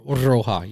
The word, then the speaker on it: roja: So red in Spanish is roja. You got to roll your R's.